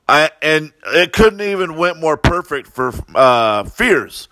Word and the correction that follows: Fires: Fires, they call him Fires. That's how you pronounce his name. Fires. I kept saying Fears, but Fires.